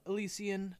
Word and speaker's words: Elysian: Elysian.